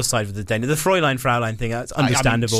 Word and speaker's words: Fräulein: Fräulein. – So I do pronounce it Fraulein for the entire book.